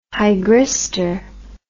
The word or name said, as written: hygristor